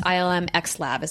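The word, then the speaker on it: ILM xLAB: Um, so X Lab helped create the experience and updated the engine uh, with the Void team, um, which which is um, ILM Cross Lab, or I think it's just ILM X Lab is how you say it actually.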